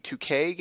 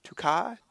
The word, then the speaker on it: Tuque: It is spelled T-U-Q-U-E, 2K Games, 2K, because it's Canadian.